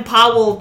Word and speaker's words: Pavel: Uh, how come fucking Chekhov can't pronounce V's? His name is fucking Pavel. Yep. There's a V in his name. – Pavel. – There's two V's in his name.